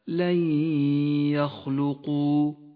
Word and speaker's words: لن يخلقوا: بنفس الآية الإدغام بغنة في قوله تعالى: لن يخلقوا. لونت النون بالرمادي لأنها لا تلفظ، وأما الياء فقد لونت بالأخضر لأنها تغن بمقدار حركتين. لن يخلقوا.